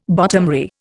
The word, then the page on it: bottomry: Indian